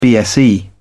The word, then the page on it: bse: British English